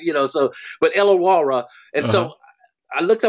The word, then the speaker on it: Illawarra: And then I read, so I said, okay, well, and how do you pronounce that Australian team, Chris, Jim, that LaMelo played for? What's the name of the team he played for? – Illawarra? – Illawarra? – Illawarra? Yeah, okay. The Illawarra.